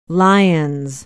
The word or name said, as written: lyons